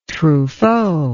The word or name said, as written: truffaut